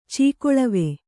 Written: ಚೀಕೊಳವೆ

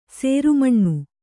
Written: ಶ್ರಮಣ

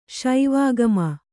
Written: ಶಿವಾಗಮ

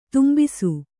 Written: ದಂಬಿಸು

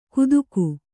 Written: ಕುತುಕು

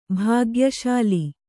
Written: ಭಾಗ್ಯಶಾಲಿ

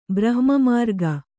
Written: ಬ್ರಹ್ಮಮಾರ್ಗ

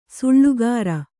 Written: ಸೆಳ್ಳುಗುರು

